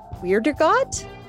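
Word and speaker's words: Wiedergott: Dan Castellaneta, Hank Azaria, Harry Shearer, Kurt Weirdergott? Did I say that right? – Yes, you did.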